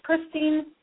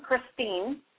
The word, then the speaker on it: Christine: My name is Christine Weick. You can find me on Facebook. My first name, Christine, C-H-R-I-S-T-I-N-E. And my last name is spelled W-E-I-C-K.